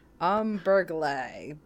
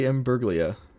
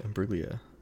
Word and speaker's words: Imbruglia: Umberglay. I am. I'm gonna just. God, yeah. I'm just gonna. – Yeah, cover the multiple choice. Natalie Imbruglia. – Imbruglia. What'd she star in? – Natalie Imbruglia, 90s TV show.